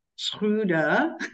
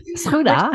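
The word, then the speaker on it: Schröder: Dennis, I think because we're Dutch, we can probably get away with pronouncing his name as Schruda. – Yeah. Schruda. kind of rhymes with strudel.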